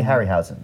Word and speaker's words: Harryhausen: Ray Harryhausen.